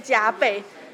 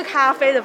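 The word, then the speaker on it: 珈琲: 那其实后面这两个字念加倍，它是玉字边的咖啡，它不是咖啡的发音，它念加倍，它是日文的汉字。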